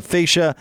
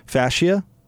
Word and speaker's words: fascia: They'll improve the curb appeal for your house with James Hardy brick, stone, and stucco exteriors, along with soffit, fascia, and rain gutters. Fascia? Did I say that wrong? – Yeah. – Fascia? – Check them out. – Is it fascia? Okay.